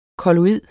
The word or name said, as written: kolloid